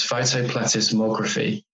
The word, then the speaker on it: photoplethysmography: And then we found out about the technique, and it's known as photoplethysmography, if I've said that correctly. – Sounds right to me. That's how I always pronounce it.